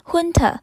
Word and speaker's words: junta: Junta，J U N T A，Junta，大家一定觉得这一个发音怎么这么特别？明明是 J，但是却没有念出这的声音。好，这一个字叫做军政府的意思是一个名词。英文里面有很多单字，它的字源是来自于其他的语言。那这一个字 Junta，它的字源是来自西班牙文，西班牙文的念法叫做 Honda。